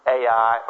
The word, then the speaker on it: Ai: My Hebrew professor instructed us that it should be pronounced I. Actually, if you pronounced it according to the Hebrew pronunciation, you'd pronounce it with a strange sound in the back of your throat. We don't have that Semitic-type pronunciation, and so we pronounce it A-I or I, either one.